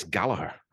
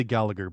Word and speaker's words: Gallagher: And I believe it's Gallagher, I think. I don't think he pronounced the G in, I think.